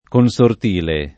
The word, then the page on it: consortile: Italian